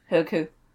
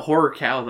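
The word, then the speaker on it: Horrorcow: It's spelled horror cow, though. – Yeah, but it's ku, because it's Scottish. – Ah. Horku. – It was being said by David Tennant. – Nah. – In his natural Scottish accent, so it's Horku. – Horku. – He's like, yeah, because it's like a ku.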